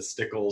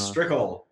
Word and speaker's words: Strickle: Well, people pronounce Strickle wrong all the time, so I'm used to the Stickle, Strickland. They always put the A and D on the end of it. Uh-huh. It's Strickle, like pickle.